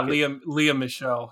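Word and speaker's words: Lea Michele: And he's going to like a fucking elevator when he gets stopped by Leah Michelle. Is that how you pronounce her name? Leah Michelle. – Yeah, Leah Michelle.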